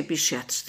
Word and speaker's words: Behçet's: And it's, well, I say Behçet's. There's like 30 ways it's pronounced. It's a Turkish name, surname of the...